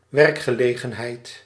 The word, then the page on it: emploi: France; /ɑ̃.plwa/